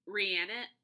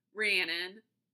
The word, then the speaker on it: Rhiannon: Another one of my absolute favorites, and it kind of kills me that I can never say her name correctly. I always want to say Rihanna. No, that was right. Rhiannon.